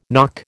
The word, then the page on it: nock: American English